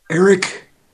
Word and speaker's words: Eric: Eric Dilbert. – E-R-I-C-S in Charlie.